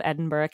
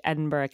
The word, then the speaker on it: Edinburgh: The most common I heard was Edinburgh. So that's how I'm going to be pronouncing it.